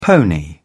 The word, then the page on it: pony: British English